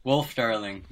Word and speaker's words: Wølfdarling: Wølfdarling, yes.